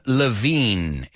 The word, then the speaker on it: Levine: Number one, entrepreneur, judge, frontman, Adam Levine. He oído gente aquí decir Levine, que tendría sentido, pero no, es Levine. No sé por qué. Ahí han decidido que es Levine, no Levine.